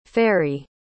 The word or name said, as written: farry